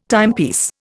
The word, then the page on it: timepiece: Indian